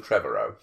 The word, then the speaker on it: Trevorrow: There's no A in it. – I like the fact you keep There's no Trevorrow. – That's how, it's just Trevorrow. That's how I hear some people say his name, and I always confuse.